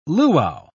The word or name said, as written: luau